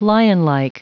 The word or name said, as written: lionlike